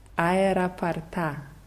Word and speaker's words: аэропорта: Also hier wird die Betonung anders. Also hier ist die Betonung auf dem letzten A. Как добраться до аэропорта? – Как добраться до аэропорта? – Aeroparta.